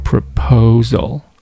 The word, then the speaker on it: proposal: 这个 my 后面的这个求婚的这个单词、 proposal，它的重音出现在第二个音节上，所以说第一个音节读的尽量要短要轻，第三个音节呢也尽量要短要轻，用来突出中间的这个音节 proposal proposal。